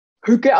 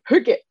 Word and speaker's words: Hygge: Ja, und der Begriff Hücke kommt aus dem Dänischen und wird Hücke ausgesprochen, also mit Üs, hat auch nichts mit hügelig zu tun oder so, sondern es wird wirklich, also es wird geschrieben H-Y-G-G-E und wird ausgesprochen wie H-Ü, GGE, ja, also Hücke.